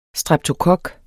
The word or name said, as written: STREPTOKOK